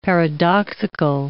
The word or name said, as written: paradoxical